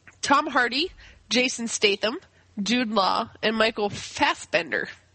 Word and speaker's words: Statham: Just to correct you there, it's pronounced Statham.